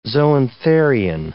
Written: zoantharian

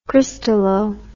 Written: crystallo

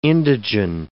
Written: indigen

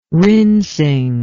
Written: rinsing

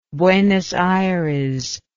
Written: buenos aires

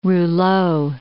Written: rouleau

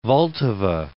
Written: Vltava

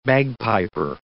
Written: bagpiper

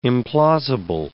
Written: implausible

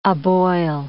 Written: aboil

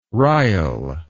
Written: ryal

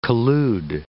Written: collude

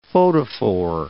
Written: photophore

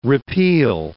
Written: repeal

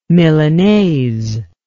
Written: milanaise

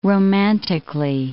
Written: romantically

